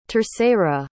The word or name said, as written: terceira